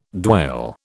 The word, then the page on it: dwale: British English